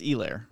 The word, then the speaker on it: Helaire: Clyde Edwards-Helaire. – It's Helaire. – It's Helaire.